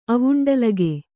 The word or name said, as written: ಆವುಂಡಲಗೆ